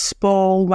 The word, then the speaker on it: Spall: Also, I feel like you picked a good actor there, Louis, because Spall rhymes with ball, call, you know.